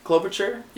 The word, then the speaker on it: Klobuchar: Amy Klobuchar? Klobuchar? – Yeah, sounds fair.